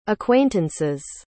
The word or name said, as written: acquaintances